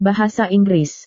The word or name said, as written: bahasa inggris